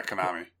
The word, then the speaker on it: Konami: Yeah, Konami.